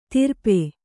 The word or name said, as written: ತಿರ್ಪೆ